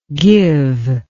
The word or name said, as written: givee